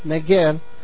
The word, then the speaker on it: Negev: Again, Abraham moved further south to the, how do you say that? Negebi, the desert, the area. Nine. But it's not, it wasn't in nine. Negev.